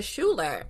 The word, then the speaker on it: Schuyler: I need y'all to know that when I saw Hamilton and there was like Elizabeth Schuyler, and then I saw the lyrics and I said, that's Elizabeth Schuler. – It's y'all are Schuler. saying the name wrong.